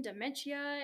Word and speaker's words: dementia: Like it's going to help you with premature aging, dementia. It's going to, did I say that wrong? – Dementia. You really got that eye in there.